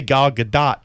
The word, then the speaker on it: Gal Gadot: I know they pronounce her name all different ways. They've said Gal Gadot, huh? Gal Gadot. Right. I say Gal Gadot, because that's how I heard her say it one time okay.